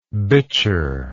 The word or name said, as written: bichir